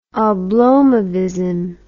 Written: oblomovism

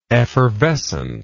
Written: effervescently

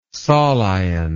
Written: solion

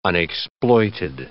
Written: unexploited

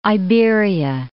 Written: Iberia

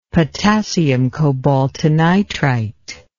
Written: potassium cobaltinitrite

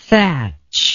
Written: Thach

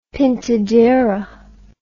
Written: pintadera

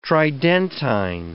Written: Tridentine

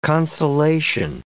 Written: consolation

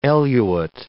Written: eluate

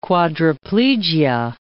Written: quadriplegia

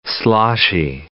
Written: sloshy

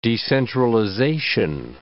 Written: decentralization

